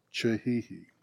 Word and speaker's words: Kihihi: I've shared this already since we got back from Africa, but we were in Chihihi. I love it. Spelled K-I-H-I-H-I, but pronounced Chihihi.